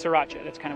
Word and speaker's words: Sriracha: Spelled S R I R A C H A, but the first R is silent, so it's pronounced Sriracha but in the United States, you will hear it more commonly referred to as sriracha. – You omit the R. You just say sriracha.